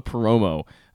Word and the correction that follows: Perdomo: uh, Perdomo, excuse me, is not a big name in Major League Baseball.